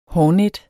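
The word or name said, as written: HÅRNET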